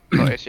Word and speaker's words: Taiz: Uh, in Taiz, is that how pronounced? – Taiz, yeah.